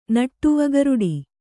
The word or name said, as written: ನಟ್ಟುವಗರುಡಿ